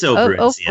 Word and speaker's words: Operencia: Am I pronouncing that? Is it Operencia or Operencia? – It's Operencia. – Operencia.